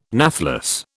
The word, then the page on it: nathless: British English